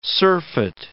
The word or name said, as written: surfeit